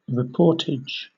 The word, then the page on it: reportage: British English